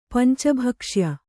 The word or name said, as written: ಪಂಚಭಕ್ಷ್ಯ